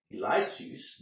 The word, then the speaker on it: Eliseus: But notice, he goes on and gives another example in verse 27, and many lepers were in Israel in the time of Eliseus. This is the Greek way of pronouncing Elisha, and the prophet, and none of them was cleansed, saving Naaman the Syrian.